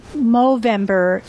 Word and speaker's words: Movember: it's called the movember foundation which rhymes with november